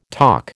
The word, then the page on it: talk: American English